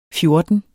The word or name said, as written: fjorten